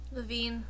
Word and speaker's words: Lavigne: Let's release things like Courtney Love's manga and Britney Spears' manga and Avril Lavigne or Levine or how do you pronounce her name? – Levine. – Levine's manga.